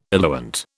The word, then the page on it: eluant: British English